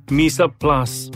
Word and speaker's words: mise en place: So what does Mesa Plus mean? It's a French pronunciation actually, and it's spelled as M-I-S-E-E-N-P-L-A-C-E.